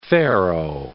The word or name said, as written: farrow